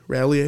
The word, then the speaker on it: Raleigh: Is that how you say it? – Raleigh, I think. – I think it might be Riley. – Riley? – R-A-L-E-I-G-H. – There's an A in there. – R-A, yeah. – There's an A, so it's Raleigh.